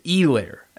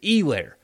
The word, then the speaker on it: Helaire: LSU apparently does not have somebody that's as good as Clyde Edwards-Helaire, which, by the way, it's not Helaire. It is Helaire, as we have learned with a Monday Night Football the other night. Steve Levy was saying it right. Everybody was ripping Steve Levy for not knowing his name. Turns out that's how it's supposed to be said, is Helaire, not Helaire, as you hear from some people.